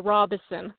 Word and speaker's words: Robison: Hi, and it's pronounced Robison.